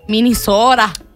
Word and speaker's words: Minnesota: You're saying it wrong. – Yay, Minnesota. – Minnesota. – Thanks.